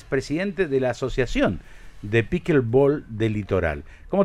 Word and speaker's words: pickleball: Pickleball. ¿Se pronuncia? ¿Cómo se pronuncia esto? ¿Cómo es? – Pickleball. – La... ¿Pickleball? ¿Así? Pickle. Como pickle, como el pickle.